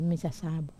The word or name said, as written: masasabu